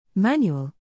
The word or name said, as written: Manual